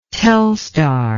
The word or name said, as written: Telstar